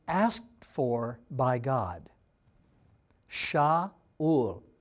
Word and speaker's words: Shaul: Saul in Hebrew is pronounced Shaul. Shaul is what it's pronounced as.